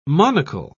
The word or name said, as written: monocle